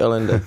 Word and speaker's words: Allende: Ano, je to Allende, ano, Allende.